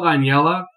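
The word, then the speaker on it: Aniela: It's called Anyella. It's not at all spelled like it sounds. It's A-N-I-E-L-A.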